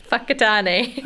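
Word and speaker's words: Whakatāne: I'm from Whakatane, New Zealand. – What's it called? – Whakatane. – How do you spell that? – Whakatane.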